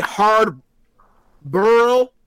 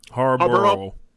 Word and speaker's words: Harbaugh: hard burl. How did he mispronounce Harbaugh? Was it Harbaugh or Harbaugh? – Harbaugh.